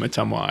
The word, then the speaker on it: Ottumwa: I actually have a friend from Ottumwa, Iowa.